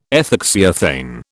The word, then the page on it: ethoxyethane: British English